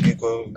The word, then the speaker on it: Miko: For me, in my case, my name is Miko. I expect to be called like that. If you call the example in Afrikaans, it's pronounced Niku. But when I tell you no, it's not like that. Because to be fairly honest, I'm not Afrikaans. It should be pronounced like I pronounce it.